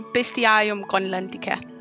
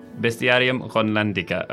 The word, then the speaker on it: Bestiarium Grönlandica: How do you say it properly? – I say Bestiarium Grönlandica. – Bestiarium Grönlandica.